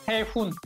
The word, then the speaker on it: Sehun: Según yo, es Sehun. Sehun. Ajá, así muy bien.